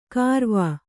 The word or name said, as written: ಖರ್ವ